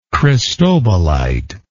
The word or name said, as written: cristobalite